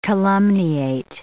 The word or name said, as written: calumniate